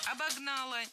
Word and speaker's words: обогнала: Вдруг нас обгоняет машина, которая вот обогнала и сразу начинает тормозить. – Обогнала, неверно, правильно, обогнала. Опять же, ударение ставим на окончание, на традиционное для этой формы место.